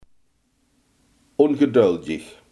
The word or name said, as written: ongeduldig